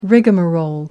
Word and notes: rigamarole: British English

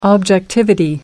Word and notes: objectivity: British English

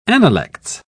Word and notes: analects: British English

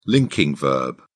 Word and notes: linking verb: British English

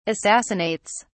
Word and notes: assassinates: British English

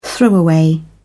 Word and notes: throwaway: British English

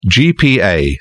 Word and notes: gpa: British English

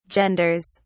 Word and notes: genders: British English

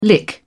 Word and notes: lick: British English

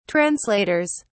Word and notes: translators: British English

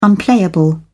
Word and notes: unplayable: British English

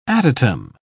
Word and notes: adytum: British English

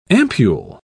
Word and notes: ampul: British English

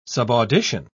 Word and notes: subaudition: British English

